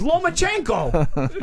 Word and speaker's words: Lomachenko: It's Lomachenko. That's it. There's one N in his name. It's right before the K.